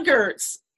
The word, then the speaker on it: Goertz: Tana Goertz.